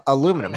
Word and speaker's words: aluminium: Uh, yeah, it's the best aluminum and titanium and gold and uh, aluminium. Um, did you say that? How do you say that? Aluminium. aluminium. Is there an extra U in there? – No, that's extra I.